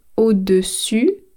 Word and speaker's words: au-dessus: Phonetically, I know it's very tricky, so I'm going to say it again. Au-dessus, so I end with the letter U, au-dessus means on the top, en dessous, it's the letters OU, that's underneath.